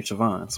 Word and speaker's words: Siobhan: I guess it's kind of like my wife's name, Siobhan, so... – Yeah, I'm sure there's people who pronounce that B all the time. – There's people who pronounce the B.